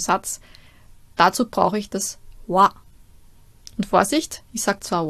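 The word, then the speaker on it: Wa (は): Nur wenn man zu diesem Satz-Tierpartikel zurückgeht, dann spricht man das Ganze wa aus.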